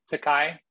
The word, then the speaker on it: Tokaj: His latest article is about the wines of the Tokaj region. Did I say that right? Tokaj? – You did say that right. I'm impressed.